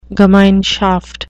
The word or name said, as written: gemeinschaft